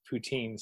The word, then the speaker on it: poutine: You know, I've always been a big fan of poutine. So, got to go to Quebec, though. – Did I pronounce that right? – Yeah, poutine. Or in French, they say poutin.